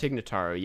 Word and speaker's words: Tig Notaro: So they replaced him with, I forget her name, Tig Notaro, I think is how you pronounce it. – Oh, yeah. I'm not familiar. Tig Notaro, I think. – Tig Notaro, yeah.